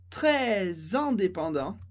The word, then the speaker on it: très indépendant: Então, très, normalmente a gente não pronuncia o S, mas em très indépendant a gente pronuncia esse S com som de Z.